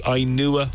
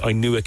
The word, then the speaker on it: Inua: We're giving you a chance to win a gorgeous one-night getaway in one of the Ainua four-star hotels. You'll find them online. It's spelled I-N-U-A, and it'll bring you to the Ainua collection of hotels right across Cork.